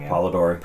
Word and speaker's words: Polidori: And then all these other weird encounters that everyone has at this time, and that how do you say his last name? – Polidori.